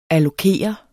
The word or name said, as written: allokere